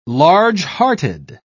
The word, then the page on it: large-hearted: British English